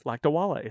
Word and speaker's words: Lakdawalla: Yeah, so Emily Lakdawalla from the Planetary Society, who I've been talking about, wanted to get her name right because it's Lakdawalla. It's not an easy name, but I got it.